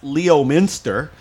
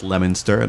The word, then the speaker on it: Leominster: It looks like it should be pronounced Leominster, but it's not. It's actually pronounced Leominster, and I only know this because David told me.